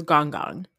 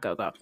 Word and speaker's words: gong gong: Yeah By the way, we said it wrong. His grandpa was Gong Gong, not Go-Go.